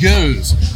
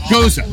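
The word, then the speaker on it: Gose: You mean Goose? It's pronounced Goose. You know, no. Goza. It's something different.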